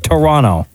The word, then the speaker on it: Toronto: Just wondering why no one in Toronto pronounces the second T in Toronto. You say it like Toronto.